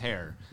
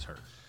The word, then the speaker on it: Herr: Her. That's okay. – I swear to God. – The correct pronunciation is Hare, but the Americanized version that my family has always used is Her.